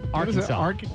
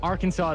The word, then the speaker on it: Arkansas: Arkansas? – Arkansas. Arkansas is what it's called.